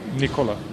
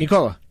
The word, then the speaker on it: Nikola: Nikola. – You're just faster. Just Nikola. – Nikola. – I'm saying it right. – Nikola. – You're hearing it Nikola. Like Ricola, you're saying Nikola. I'm saying Nikola.